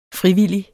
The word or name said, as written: frivillig